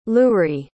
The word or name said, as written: loury